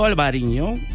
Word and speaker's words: Albariño: Claro, ya están en plena Festa do Albariño.